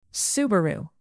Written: subaru

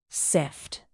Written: sift

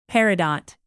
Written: peridot

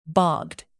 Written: bogged

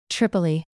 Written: tripoli

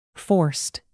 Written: forced

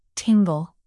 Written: tingle

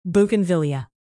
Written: bougainvillea

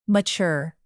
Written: mature

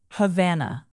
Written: havana

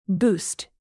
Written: boost

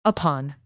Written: upon

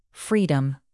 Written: freedom

